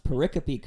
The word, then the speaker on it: pericope: And I'm glad you pronounced pericope correctly, too.